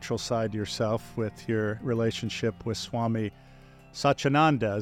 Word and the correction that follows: Sachidananda: Is that the correct pronunciation? – Close, Sachidananda. Uh-huh.